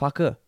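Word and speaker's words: Farquhar: which is the correct way of pronouncing. No, yeah. I think it's pronounced Faker.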